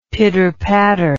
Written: pitter patter

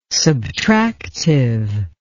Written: subtractive

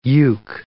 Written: yeuk

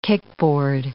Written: kickboard

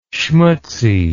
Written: schmutzy

